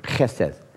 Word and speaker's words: chesed: It's the Hebrew word, chesed. You say it right, you spit on your friends.